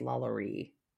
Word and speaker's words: LaLaurie: And, you know, I think I was saying the name wrong because it's spelled LaLori. But I think because she's Spanish. – It's French. Is it French? Is her husband French? – That's why I get confused. Because then I was like, is it like La Laurie? – I think that's right. Because I think if I remember hearing it correctly in like the American Horror Story, I think it's La Laurie.